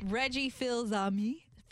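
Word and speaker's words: Reggie Fils-Aimé: Reggie Filzami.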